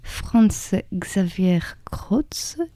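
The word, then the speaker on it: Franz Xaver Kroetz: un texte de Franz Xaver Krautz, désolée si je l'ai mal prononcé.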